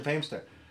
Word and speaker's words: Feimster: I've been saying Fortune's last name wrong forever. – Yeah, Fortune Famester.